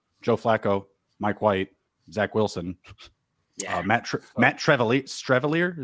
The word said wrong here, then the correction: Streveler: Yeah, Streveler.